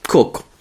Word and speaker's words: Koch: The next Orion will have four people on board, as its voyages around the moon, Koch, whose name is pronounced Cook, hoped to be among them.